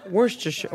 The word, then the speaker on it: Worcestershire: Worcestershire. How do you say it? – Worcestershire. – Do British people just say Worcester sauce?